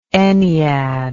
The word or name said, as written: enneadic